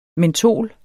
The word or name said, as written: MENTOLET